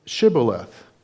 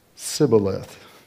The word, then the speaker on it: Shibboleth: "Say Shibboleth." And they would say Sibboleth, for he could not pronounce it right.